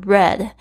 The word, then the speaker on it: read: 他这个 read 就是过去式要念成 read。